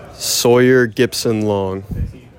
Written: Sawyer Gipson-Long

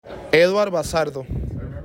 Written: Eduard Bazardo

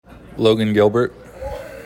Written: Logan Gilbert

